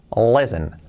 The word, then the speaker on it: listen: Cuidado que se pronuncia, bueno, se escribe listen, listen, listen, listen to the music y no es listen, es listen. La L bien marcada, la la la la la, listen. La I como una E. Y luego importante que la T no se pronuncia nunca.